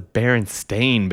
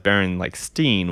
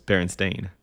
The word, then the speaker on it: Berenstain: But that's how it's pronounced, right? – That's how it's pronounced. That's how it's spelled. I always just thought it was Baron like Steen with an E.